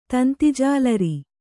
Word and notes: ತಂತಿಜಾಲರಿ: kannada

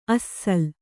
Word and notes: ಅಸ್ಸಲ್: assal

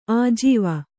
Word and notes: ಆಜೀವ: ājīva

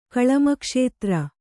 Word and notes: ಕಳಮಕ್ಷೇತ್ರ: Kannada

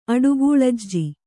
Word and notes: ಅಡುಗೂಲಜ್ಜಿ: aḍugūlajji

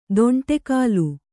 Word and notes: ದೊಂಟೆಕಾಲು: dōṇṭe kālu

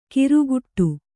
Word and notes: ಕಿರುಗುಟ್ಟು: kiruguṭṭu

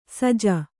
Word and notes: ಸೋಜಿ: sōji

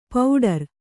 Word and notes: ಪೌಡರ್: pauḍar

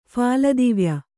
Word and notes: ಫಾಲದಿವ್ಯ: phāla divya

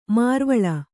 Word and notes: ಮರವಾಳ್: maravāḷ

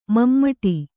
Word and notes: ಮಮಟಿ: mamaṭi

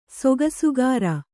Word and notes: ಸೊಗಸುಗಾರ: sogasugāra

